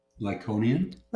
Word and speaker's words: Lycaonian: Lycaonian? – Lycaonian language.